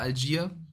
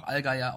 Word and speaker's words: Allgeier: Also erstmal ja, äh, du hast recht, äh, Tyler Algier, wie er in Amerika ausgesprochen wird, hat ein Video eben hochgeladen, wo er sagt, ähm, dass er deutsche Vorfahren hat und deswegen auch Algeier ausgesprochen werden kann.